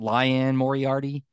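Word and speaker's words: Liane Moriarty: I think she pronounces it Lyanne Moriarty.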